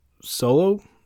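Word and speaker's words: Solow: And Herbert Solo, that's how it's pronounced, Luna, Solo, was hired to help locate new projects for the studio.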